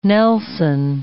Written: Nelson

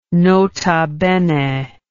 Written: nota bene

